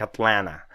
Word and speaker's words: Atlanta: я знаю, что произносится как Атлана, Hunter in Atlanta.